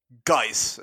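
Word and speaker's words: Guice: Geese is how you say it.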